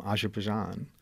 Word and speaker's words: Azerbaijan: And not that there's not plenty to talk about Manhattan, but originally from Azerbaijan.